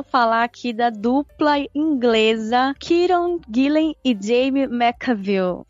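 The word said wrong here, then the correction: Jamie McKelvie: O último não, Jamie McKelvey.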